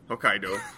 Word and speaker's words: Hokkaido: Hokkaido.